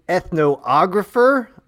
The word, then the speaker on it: ethnographer: Or is it ethnographer? – Ethnographer. – Thank you. – Like photographer?